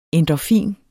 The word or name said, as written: endorfin